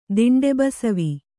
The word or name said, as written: ದಿಂಡೆಬಸವಿ